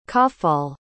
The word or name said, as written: cafall